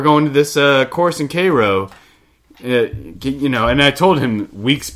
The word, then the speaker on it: Cairo: Our Seminole Cup Bowl, we went to Cairo, Georgia. It's not Cairo, even though it's spelled like Cairo.